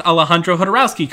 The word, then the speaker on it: Alejandro Jodorowsky: I love Alejandro Jodorowsky, and I pronounced Alejandro Jodorowsky correctly.